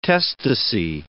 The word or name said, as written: testacy